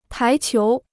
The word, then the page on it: 排球: pái qiú